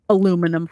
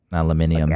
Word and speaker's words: aluminium: Aluminum foil. – Aluminium. – Get out of here. It's aluminium. Every other thing is an eum, except for the one that we decided was aluminum.